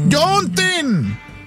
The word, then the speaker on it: Juneteenth: En todo el país hoy se conmemora el Juneteenth. ¿O cómo se dice? – Juneteenth.